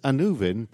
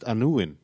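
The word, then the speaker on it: Annwn: Well, it has you know, the Land of Death, which was written as Anuvin, but I know it's pronounced Anuin, and they had Aron, the Death Lord or the Lord of Death.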